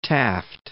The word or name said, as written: Taft